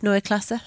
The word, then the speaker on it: Neue Klasse: And Jonathan, I do have to ask you, my pronunciation of Neue Klasse, how is it? – Fantastic.